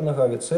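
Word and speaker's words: Nohavica: Во-первых, потому что все-таки ударение в имени, фамилии автора на второй слог Нагавицы, Яромир Нагавица, это чешский бард.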